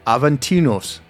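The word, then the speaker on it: Aventinus: It's Aventinos. – Aventinos. – Yeah, it's U.S., but it's pronounced O.S., and we had to look that up.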